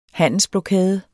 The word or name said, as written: HANDELSBLOKADER